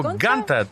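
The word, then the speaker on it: Giunta: А, Фьюрио Ганта, так произносится.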